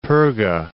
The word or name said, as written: Perga